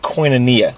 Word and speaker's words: koinonia: So it's the Greek word for fellowship, and it's pronounced koinonia, which means fellowshipping, intimacy, communion, intercourse.